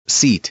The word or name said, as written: seat